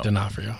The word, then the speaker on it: D'Onofrio: D'Onofrio? Did I say it wrong? I said DeForno. – DeForno. Yeah, it's D'Onofrio. – D'Onofrio.